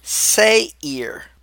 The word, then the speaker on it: Seir: And it's pronounced in Hebrew, Seir.